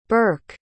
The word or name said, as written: bourke